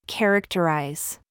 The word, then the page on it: ZOOS: American English